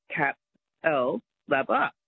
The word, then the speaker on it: Capalaba: And yeah, just to learn how to say Capalaba. – Oh, well, it might not be my show you're enjoying then. – If you look at it, it's C-A-P-A-L-B-A, Cap-L-Laba.